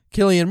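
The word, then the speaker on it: Cillian: But like, you know, we're talking about Peaky Blinders, Killian Murphy, it's C-I-L-L. So all the C words, you know, the letter C is always pronounced K.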